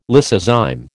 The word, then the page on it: lysozyme: American English